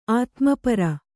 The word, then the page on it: ಆತ್ಮಪರ: Kannada